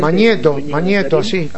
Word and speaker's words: Magneto: Magneto, no recuerdo cómo se pronuncia bien correctamente. – Magneto, así nomás.